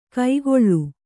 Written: ಕೈಗೊಳ್ಳು

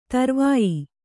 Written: ಧ್ರೌವ್ಯ

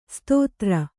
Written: ಶತಧಾರ

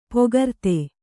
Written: ಪೊಗರ್ತೆ

